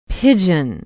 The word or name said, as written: pigeon